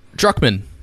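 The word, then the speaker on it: Druckmann: Druckman. Put too many syllables in it.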